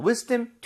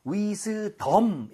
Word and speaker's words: wisdom: wisdom tooth 할 때 발음 좀 주의해 주세요. 여기다가 강세를 줘서 wisdom 이렇게 하지 마시고요. dom. 이렇게 뒤에는 힘이 좀 빠져야 됩니다.